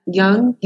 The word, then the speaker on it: Jung: Jung.